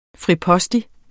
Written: fripostig

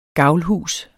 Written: gavlhus

